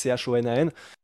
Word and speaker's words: Chonan: Mais vous allez voir, de toute façon, vous tapez Ryo Shonan, Anderson Silva Shonan, comme ça se prononce, Chonan.